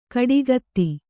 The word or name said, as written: ಕೊಡಗಿತಿ